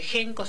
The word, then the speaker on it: Henko: También nos pueden escribir por Instagram a @espacio.genco.ok, que Genco se escribe H-E-N-K-O.